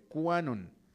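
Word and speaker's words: QAnon: Canon, que se escribe con Q, quizá tal vez fuera Quanon, más, más correctamente, Quanon.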